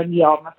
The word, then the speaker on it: Guillaume: E poi vi voglio ricordare che a gennaio uscirà l'album L'oscurità di Guillaume, che tu hai pronunciato alla perfezione, si scrive Guillaume ma si pronuncia Guillaume, quindi dopo Amor Amor arriverà l'intero album sia in forma fisica che digitale.